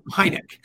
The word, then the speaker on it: Heinecke: And it is Stu Heinecke. No, Heinecke. No, Heinecke. Stu Heinecke. That's how you say his name.